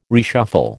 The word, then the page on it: reshuffle: American English